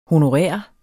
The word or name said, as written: honorær